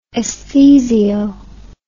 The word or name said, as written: esthesio